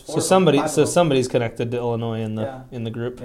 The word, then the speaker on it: Illinois: Illinois, how do you say it? – Illinois.